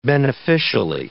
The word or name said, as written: beneficially